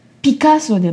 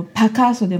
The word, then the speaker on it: PACASO: ピカソでもパカソでもなくピカソなんですね。ピカソなので、はい、 PACASO って書いてるけど発音はピカソっていう風に言えてしまうっていうですね。